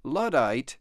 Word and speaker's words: Luddite: 在英语当中有一个词专门用来形容反对科技进步的人叫做 Luddite. Luddite is spelled L-U-D-D-I-T-E.